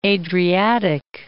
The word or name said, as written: Adriatic Sea